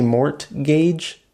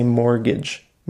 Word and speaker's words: mortgage: We don't pronounce the T. It looks like it should be mortgage, but we don't say the T. We just say mortgage.